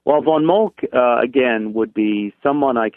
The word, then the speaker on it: Moltke: Would you mind telling our listeners a little bit about, and I'm probably not going to pronounce his name properly, but Moltke, is that how you say that? Count Moltke? – Oh, yes. James Helmut von Moltke.